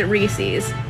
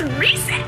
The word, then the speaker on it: Reese's: I've heard it, people there and here pronounce it Reese's. Why are we saying Reese's? It's Reese's.